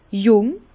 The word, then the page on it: jùng: Bas-Rhin; Herrlisheim